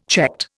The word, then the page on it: checked: Indian